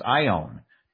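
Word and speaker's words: ion (αἰών): This word that we see as age in our Bibles in the Greek, I apologize, Mary, for my mispronunciation, is pronounced ion. It comes into English as A-I-O-N.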